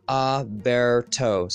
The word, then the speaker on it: Aberto: And the pronunciation is Aberto. So like it's A-bear.